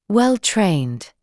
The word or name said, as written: well-trained